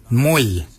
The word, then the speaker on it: mooly: Warrigal is spelled W-O-R-R-A-G-O-L, Warrigal. Warrigal is your upper mouth. For your nose, we say moil. Moil is your nose. Moil is spelled M-O-O-L-Y, moil.